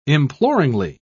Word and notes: imploringly: British English

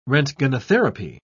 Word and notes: roentgenotherapy: British English

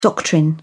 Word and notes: doctrine: British English

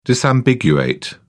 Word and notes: disambiguate: British English

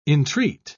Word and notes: intreat: British English

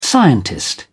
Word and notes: scientist: British English

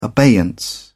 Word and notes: abeyance: British English

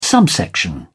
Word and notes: subsection: British English

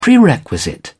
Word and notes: prerequisite: British English

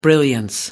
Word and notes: brilliance: American English

